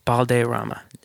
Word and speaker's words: Balderrama: How do you pronounce your last name? – Balderrama. – Balderrama. – There it is. – Okay, I had it right. I didn't want to butcher it. – You could roll your R's too if you want.